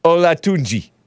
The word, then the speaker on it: Olatunji: uh, so Logan Paul and Olajide, Olajide, KSI, Olatunji. No, why am I pronouncing it all Italian? Olatunji.